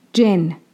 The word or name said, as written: Jinn